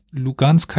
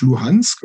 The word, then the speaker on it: Luhansk: Aber auf 2014, also auf die Annexion der Krim, folgte dann ja der Separatismus weiter im Osten, also im Donbass und Lugansk heißt die Stadt, wenn ich sie richtig ausspreche? – Ja, sie heißt auf Ukrainisch Luhansk.